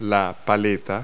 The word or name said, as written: paddle